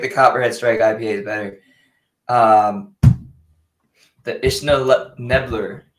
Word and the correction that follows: Isla Nublar: IPA. – I actually lost the coaster. – The I was like, am I having a stroke? – Isla Nublar. – Isla Nublar. I'm pronouncing it how it's spelled.